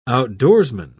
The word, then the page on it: outdoorsman: British English